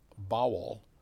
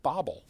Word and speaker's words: Bawel: But his name was Ed B A W E L, but his it was pronounced Bowel, but people would pronounce it Bobble.